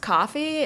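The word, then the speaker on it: Ko-fi: When I first was looking it up, it didn't dawn on me that it was like it was pronounced coffee, because the way it's spelled, it's kind of like how it sounds. So it's K-O-F-I, and I was like, what the fuck is this?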